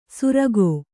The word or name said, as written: ಸುರಗೋ